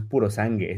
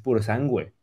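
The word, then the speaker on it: Purosangue: En primer lugar, nos hablaron de el Ferrari, que ya sé cómo se pronuncia, llevamos diciéndolo mal todo el tiempo, no es puro sangue, es puro sangüe.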